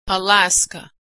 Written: Alaska

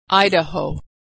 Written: Idaho

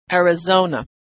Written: Arizona

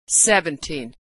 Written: 17

